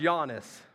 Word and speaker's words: Giannis: So he told me that the guy's name is pronounced Giannis.